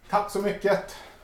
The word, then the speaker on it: tack så mycket: Tack så mycket.